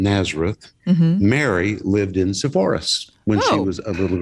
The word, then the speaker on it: Sepphoris: You talk about the village of Nazareth and Sepphoris. Is that how it's pronounced? – Right. – Sepphoris? – Sepphoris.